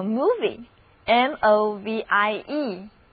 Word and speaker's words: movie: 因此，英语中电影这个单词就叫做 movie，M-O-V-I-E，它里面也含有 M-O-V 这个表示动的词根。字母 O 仍然读作长的 U，而词根 MOV 表示动， IE 是一个名词后缀，所以能够再现物体运动的东西就是电影。